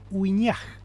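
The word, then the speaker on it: Huñaij: El Uñaj, se escribe H-U-Ñ-A-I-J, Uñaj.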